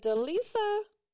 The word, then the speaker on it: DeLisa: Is it DeLisa? – That's right.